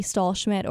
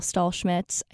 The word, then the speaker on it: Stahlschmidt: Um, but Facebook is under Abby Stahlschmidt or Abigail Stahlschmidt.